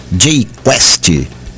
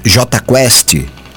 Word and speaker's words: Jota Quest: O conjunto nasceu com o nome de Jota Quest, com uma pronúncia em inglês, Jay Quest, por inspirações ou inspiração do desenho animado Johnny Quest. Mas para não serem processados pela Ana Bárbara, adotaram de vez a pronúncia em português Jota Quest.